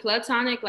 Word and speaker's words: platonic: But is it platonic or plutonic? I don't know why I keep saying plutonic. – I always pronounce it platonic, like P-A-L, but. – Plato. From Plato.